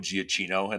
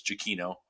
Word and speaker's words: Giacchino: I always call him Michael Giacchino and like pronounce every possible syllable in there, but it's Giacchino.